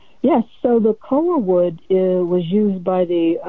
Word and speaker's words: koa: Now, you also talk about this, I don't know if I'm pronouncing it right, koa wood, which is legendary. – Koa.